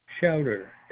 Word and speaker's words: Schauder: It's Richard, R-I-C-H-A-R-D. – R-I-C-H-A-R-D, and your last name? – Chowder, S-C-H-A-U. D-E-R.